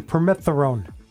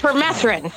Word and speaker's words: permethrin: I say that right? Yes, you did. Close. Okay. so that would be a no. It's permethrin. Permethrin.